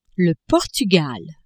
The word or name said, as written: Portugal